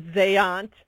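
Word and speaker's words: Zajonc: And Robert Zayant, which you taught me to pronounce before the show, he's a researcher.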